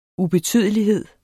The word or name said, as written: ubetydelighed